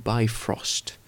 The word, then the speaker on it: Bifrost: En nou ja, het wordt ook in Marvel wordt het zo uitgesproken, Bifrost.